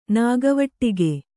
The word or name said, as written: ನಾಗವಟ್ಟಿಗೆ